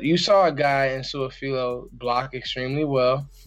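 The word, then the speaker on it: Su'a-Filo: this guy Xavier Suafio. Su, Su, how do you say his name, Joe? Suafilo. Suafilo. Suafilo.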